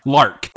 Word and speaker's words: Lark: Lark. – L-A-R-K. Because at first I was thinking John Locke, who was a character from Lost. But Lark, that's a joke. So I was confused on pronunciation. But John Lark is the one who called for the annihilation of the current world order.